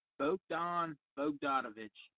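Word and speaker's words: Bogdan Bogdanović: Bogan Bobanovich? – Bogdanovich? How do you say his name? – Bogdan Bogdanovich.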